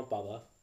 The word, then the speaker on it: baba: How do you say grandma? – Grandma Baba. – Baba. – Yeah, which is confusing because that's dad in like Arabic.